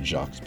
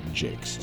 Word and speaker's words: Jacques: Now, I had pronounced it Jacques before, but listening to him being interviewed, it is Jakes, so I apologize for the previous times when I probably got it wrong.